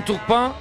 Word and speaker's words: Turpin: Bueno, oiga, hoy va a estar Clément Turpin repartiendo justicia. – ¿Cómo se árbitro francés? Clément Turpin. – Clément Turpin. – Se escribe Turpin, pero se lee Turpin.